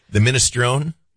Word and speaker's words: minestrone: And they had a minstroni soup that was fabulous. Yeah. Also what I could afford. – the minestrone? – Minestrone.